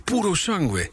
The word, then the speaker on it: Purosangue: Hij heet Purosangue.